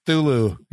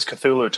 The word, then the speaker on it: Cthulhu: Even though I think they mispronounce it. How do you pronounce the name? – Did they say Thulu? – He said Thulu. Yeah. – Yeah. It's always Cthulhu to me. I mean, I'll pronounce it that way till forever.